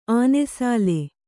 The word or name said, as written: ಆನೆಸಾಲೆ